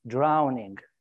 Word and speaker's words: drowning: So this is how I'm starting the day with bad pronunciation, because you said ghast. It's not ghast. It's ghost. And it's not drawing, you know, it's drowning.